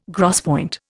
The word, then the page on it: gros point: Indian